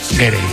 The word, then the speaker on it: Gere: estaba pensando yo que si te apellidas Ger, bueno, ¿cómo se dice Ger o Gerde? – Gere. Me ha hecho gracia porque has dicho Richard Ger y luego el hijo era Gere. – Yo creo que es Richard Gere.